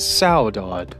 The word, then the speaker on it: saudade: The way you pronounce it is saudade. And it is spelled S-A-U-D-A-D-E, saudade.